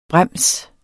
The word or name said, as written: brems